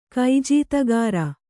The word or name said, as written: ಕೈಜೀತಗಾರ